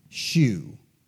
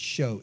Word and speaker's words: shew: Now, some people look at that S-H-E-W as it's spelled in the Bible, the old British spelling, and they want to say shoe. That's not the right way to say it. Just like S-E-W is pronounced so, so S-H-E-W is so, is show, showbread.